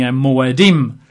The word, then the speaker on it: moedim: It's translated from the Hebrew, surprise, surprise, the Hebrew word being moedim, which is spelled in English, M-O-E-D-I-M, M-O-E-D-I-M. The I-M is just the plural form.